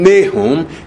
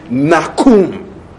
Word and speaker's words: Nahum: If you want to pronounce his name in Hebrew, you are not going to call him Nahum. You are going to call him Nakum. Nakum.